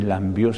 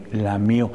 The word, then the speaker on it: lamió: No se dice me lambió, se dice me lamió.